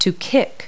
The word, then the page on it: kick: verb